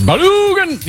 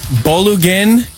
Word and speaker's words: Balogun: I think it's Balogun. You got to say it like Hadouken in Street Fighter. I think Caleb. I think it's actually Balogun.